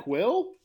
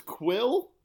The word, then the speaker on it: Kuiil: It's spelled K-U-I-I-L, and that's all. I think that's the way I heard it's pronounced. – That sounds like Quill to me.